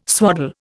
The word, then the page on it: swaddle: Indian